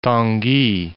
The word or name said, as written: Tanguy